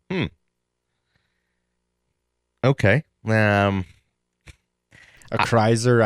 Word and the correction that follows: Acrisure: I don't know how you say it, Ac- but it's A C R I S U R. Acrisure. I think you got it.